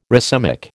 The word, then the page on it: racemic: American English